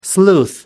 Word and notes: sleuth: American English

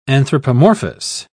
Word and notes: anthropomorphous: British English

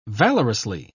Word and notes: valorously: British English

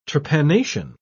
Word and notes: trepanation: British English

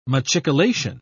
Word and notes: machicolation: British English

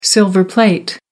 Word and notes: silver-plate: American English

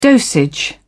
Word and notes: dosage: British English

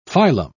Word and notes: filum: British English

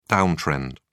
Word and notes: downtrend: British English

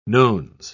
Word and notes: nones: British English

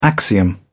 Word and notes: axiom: British English

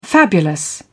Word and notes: fabulous: British English

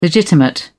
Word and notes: legitimate: British English

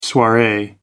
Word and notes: soiree: American English

